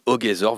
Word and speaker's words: Ogezor: Mais à présent, il faut vous appeler Ogezor, enfin O-G.E-Z.O-R. Alors pourquoi ce nom et comment ça se prononce ? – Alors, on va commencer par la prononciation, c'est Ogezor.